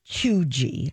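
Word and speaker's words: cheugy: But it's pronounced Chuji.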